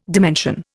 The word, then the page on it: dimension: Indian